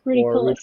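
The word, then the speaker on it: ruficollis: I believe it's, I'm pronouncing it right, it's a Rudicolis. – Rudicolis, yeah.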